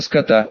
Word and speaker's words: Скотта: уцелевшего в побоище капитана Роберта Скотта. Ударение, как вы понимаете, на последний слог, это ему очень идет, и прочие воины, постоянно ротировавшиеся, то приезжая, то, наоборот, уезжая.